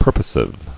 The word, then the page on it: Purposive: pur·po·sive